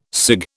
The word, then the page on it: cig: British English